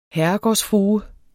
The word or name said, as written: herregårdsfrue